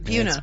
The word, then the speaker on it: Buena: That's how you say it, Buna.